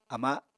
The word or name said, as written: ama